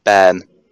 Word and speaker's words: bairn: Ben. – What? – Ben. – Ben? – Ben, yeah, it's spelled B-A-I-R-N.